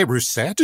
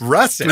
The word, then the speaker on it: Roussette: Do you say Roussette? – Yes, how do you say it? – At three raw potatoes. – Russet. – No, okay. It's Roussette.